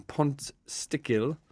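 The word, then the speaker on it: Pontsticill: So Derek the Weather outside a pub called Red Cow in Pontstickill. That's how you pronounce it.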